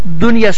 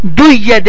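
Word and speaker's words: دنيا: Dunya sözünün ortasında ve sonra ise ye harfi gelir. Biz bunu duyye diye telaffuz etmirik. Dunya diyerek telaffuz edirik veya sinwanun,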